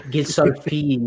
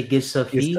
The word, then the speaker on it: Gisolfi: But that's why I hope with De Rossi and the new sporting director, Gisolfi, I hope I'm saying it correctly, from Nice, that is confirmed coming. – You do speak French. – Gisolfi, you can say Gisolfi.